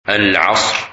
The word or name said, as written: Asr